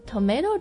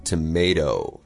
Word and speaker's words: tomato: Right, so North Americans always say tomato, tomato, but British people will say tomato. – Tomato. – So two things to keep in mind there, the ah sound is different, and also t, it's a t, not a d.